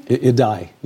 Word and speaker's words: Idai: In fact, it is now hitting Mozambique, where Hurricane Idai, is it pronounced Idai? – Idai. Yeah. – Idai.